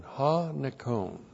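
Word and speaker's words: Ha, nikon (ὁ νικῶν): Now, this term is a translation of ha nikon. This is an articular participle of the verb nikao. Ha, N-I-K, long O-N. Ha, nikon.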